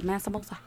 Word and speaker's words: Mansa Musa: Mansa Musa.